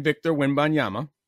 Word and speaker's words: Victor Wembanyama: It's the same thing, is that if we're going to talk about my man, Victor Wembanyama, Mm-hmm. He's so good at pronouncing that.